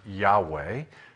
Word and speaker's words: Yahweh: It is, in Hebrew, the letters YHWH, which is pronounced Yahweh. Well, we've inserted vowels, which is Yehovah.